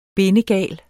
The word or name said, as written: bindegal